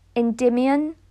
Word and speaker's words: Endymion: Oh, and then at your bottom one, Endymion is how it's always been pronounced in my circles. I did do classics for a while, so that when we're talking about the myth has always been Endymion.